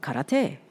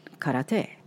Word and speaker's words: karate: We talk about karate. That's how we pronounce it. – Oh, right, right. Right. When we talk about the martial art, karate.